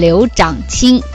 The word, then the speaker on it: 刘长卿: 我们经常会脱口而出，他的名字叫刘长卿。不对，现在已经把他的名字进行了正音，他的标准的读音应该叫做刘长卿，刘长卿。